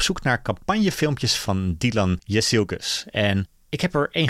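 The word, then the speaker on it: Yeşilgöz: Ik ben Dylan Yesilgus Segerius, nummer 5 van de VVD. – Ja. Daar hebben we het antwoord. – Ja, zij zegt Yesilgus. Yesilgus. Maar het is eigenlijk zonder klemtoon.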